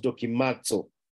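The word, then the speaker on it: dokimazo (δοκιμάζω): Dokimazo and it's pronounced dokimazo,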